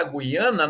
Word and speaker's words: Guiana: No caso da Guiana, nós estamos respeitando a forma como aqueles povos designavam uma terra de muitas águas, como é o caso, e eles pronunciavam Guiana. Portanto, meu amigo Marcelo está pronunciando corretamente.